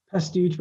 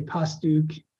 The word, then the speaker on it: Pastuch: Pastuch, but my grandma used to say Pastuch. And I mean, however you want to say it, doesn't matter.